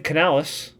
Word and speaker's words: Canales: How do you say his last name? Dave what? – Dave Canales.